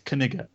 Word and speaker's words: Kniga: It's not a silent K. It's Kaniga.